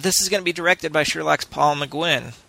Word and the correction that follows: McGuigan: So, McGuigan, pronounce it right.